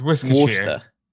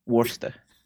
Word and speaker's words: Worcester: It's Worcester. – Worcester.